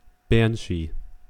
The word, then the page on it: banshee: American English